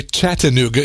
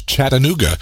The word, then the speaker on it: Chattanooga: You got to say it right. Don't say Chattanooga. It's Chattanooga.